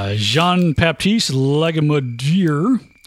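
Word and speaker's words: Jean-Baptiste Lagimodière: Jean-Baptiste Legamadier. I'm sure I said that right. – Sure, that was correct, yeah.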